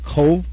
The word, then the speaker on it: Cobh: Now, although it's spelled C-O-B-H, you pronounce it Cove? – Yes, with a V sound. I understand in Gaelic that they don't have the letter V, but it is pronounced like we would pronounce Cove.